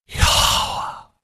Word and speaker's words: Yahweh: The Jewish people will tell you, actually they won't, but they will show you that it actually sounds like the wind. It is Yahweh. Okay, that's how you say his name.